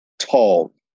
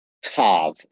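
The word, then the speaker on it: tav (ת): The translation note in the NET says, the word translated mark in Hebrew is the letter tov. Okay. Well, it's tav is the pronunciation. The letter is called tav.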